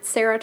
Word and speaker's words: Sarah: It's at Sarah Tarkoff.